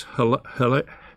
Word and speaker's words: Hallel: The word praised in the Hebrew means hallel. Hale, how, how do you say those in the Psalms? That H A L L E L. Hallel. Hallel. Okay.